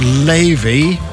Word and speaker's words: Laufey: It's by an artist called Levy, which is spelt L-A-U-F-E-Y.